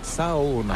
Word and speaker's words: sauna: Loona, l'île Sauna. Et comment on dit Sauna? – En fait, on prononce chaque lettre, donc c'est Sauna.